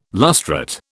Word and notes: lustrate: British English

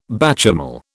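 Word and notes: béchamel: British English

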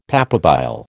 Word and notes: papabile: American English

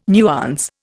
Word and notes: nuance: Indian

